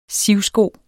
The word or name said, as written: sivsko